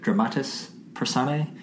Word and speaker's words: dramatis personae: So we've jumped in, and we've done the dramatis personae. Blah blah blah. How do you say that? Dramatis personae.